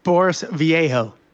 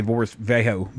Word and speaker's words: Vallejo: Boris Vallejo. – Yeah. In Kentucky, we'd say Boris Vallejo, but either way.